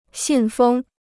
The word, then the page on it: 信封: xìn fēng